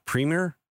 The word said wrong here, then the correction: Primer: Oh yeah, Primer. – Primer. Mm-hmm. Yep.